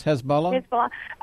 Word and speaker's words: Hezbollah: It scares me because if we get someone like Hillary Clinton involved, I mean, her liberal ways and everything, that's exactly what Hezbollah, and how do you say that? – Yes, Hezbollah.